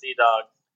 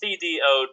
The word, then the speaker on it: CDogg: Everything, my name is CDogg. It's CDogg, and, you know, like Snoop Dogg, of course. Not D-A-W-G, like those hillbillies up the road spell it.